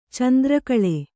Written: ಚೆಂದ್ರಕಾಳಿ

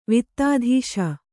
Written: ವಿತ್ತಾಧೀಶ

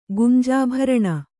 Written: ಗುಂಜಾಭರಣ

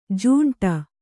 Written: ಝಿಂಟಿ

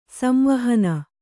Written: ಸಂವಾಹನ

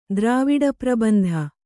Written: ದ್ರಾವಿಡಪ್ರಬಂಧ

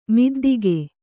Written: ಮುದ್ದಿಗೆ

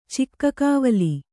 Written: ಚಿಕ್ಕಕವಲಿ

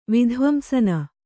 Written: ವಿಧ್ವಂಸನ

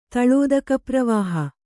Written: ತಳೋದಕಪ್ರವಾಹ

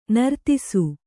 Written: ನಿರ್ದೆಸೆ

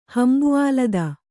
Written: ಹಂಬುಆಲದ

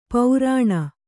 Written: ಪೌರಾಣ

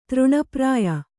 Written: ತೃಣಪ್ರಾಯ

